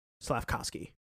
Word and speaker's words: Slavkovsky: Juraj Slavikovsky. – That's far off. That's incredibly far off. – His last name is Slavkovsky. Slavkovsky.